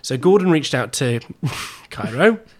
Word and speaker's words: Cheiro: Now I can't stress this enough. Her name? – Chiaro. – Cairo. Cairo. It's spelled C-H-E-I-R-O. – Not Charo. – Not, crucially, not Charo.